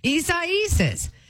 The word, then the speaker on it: Isaias: Am I saying that right? Isaias? Isaias. Yeah, I don't know who names these hurricanes either.